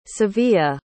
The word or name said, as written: sevilla